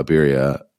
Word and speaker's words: birria: I'm so regretful that it didn't become a birria taco.